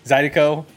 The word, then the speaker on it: Zydeco: Zydeco. Is that how you say it? Sorry. – Yeah. – Had a couple beers. – Wrong with you? – Zydeco, whatever. I put the emphasis in the wrong word.